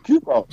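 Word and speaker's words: Cuoco: How do you say it? Coco? Kuko? Kiko? – Kuko. – Something, Kuko. Sure. – I say Kuko.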